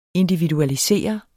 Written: individualisere

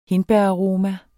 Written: hindbæraroma

